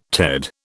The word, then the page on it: Ted: British English